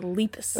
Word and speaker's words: Lepus: Interestingly, they pronounce it like if you were to call the rabbits leapers, but without the R. – The Lepus.